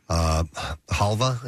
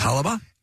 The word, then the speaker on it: halva: And so now the two have introduced babka a la mode, which features Essence chocolate, Havla, and I guess I'm assuming I'm saying this correctly, Babka. Halva, I guess. – Halva? – And H-A-L-V-A is how it's spelled.